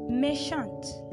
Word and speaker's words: méchante: Now, you can see that there is a difference between the pronunciation of the word. Yeah, that's actually because when you're writing or spelling the word méchant for that of the male, you spell it this way, M-E-C-H-A-N-T, and then that of the female is spelled M-E-C-H-A-N-T-E. Okay, that's what actually makes the pronunciation differ.